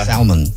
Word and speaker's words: salmon: Gosh, somebody else texted in at 41061 and said, I broke up with somebody because they pronounced the L in salmon. Salmon.